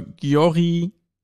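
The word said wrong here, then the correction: Győr: Eto FC? – Ja, hast du recht, hast du falsch gesprochen. – Ja, heißt er richtig? – Eto FC Jör. – Jör, okay. Genau.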